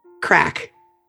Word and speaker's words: craic: We're going to use my personal favorite, crack. – Crack? – Yeah, it's spelled C-R-A-I-C.